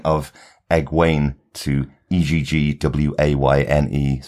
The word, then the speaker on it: Egwene: On the show, she's being called Egwene, so that is what we have to try and stick with.